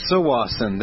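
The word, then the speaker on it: Tsawwassen: It's actually pronounced Sawasin there.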